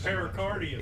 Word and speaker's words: pericardium: And I went online and it says, if you leave the perichondrium. – Pericardium. – It is. Pericardium.